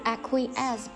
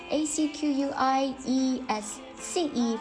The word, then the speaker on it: acquiesce: 今天的第三个单词 acquiesce，不知道大家记住了没有？ acquiesce， a c q u i e s c e，放在那边是不发音的。